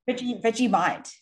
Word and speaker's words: Vegemite: Vegemite.